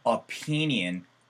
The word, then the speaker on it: opinion: 그래서 I like to get your 혹은 I like to get your 하고 나서 이건 오 하면서 주둥이가 모아지는 게 아니고요. 에서 opinion 사운드거든요. 요거 발음 한번 들려드릴게요. – opinion. – 그래서 opinion이지 opinion이 아니에요. 그 다음에 opinion이 아니고 opinion이 돼야 되거든요.